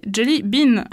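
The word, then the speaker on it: jellaebaeni: On peut aussi citer Jellae Baen, à prononcer Jelly Bean, ainsi que ses espèces proches, Jelly Donut et Jellyfish, des scarabées qui se nourrissent de blobs.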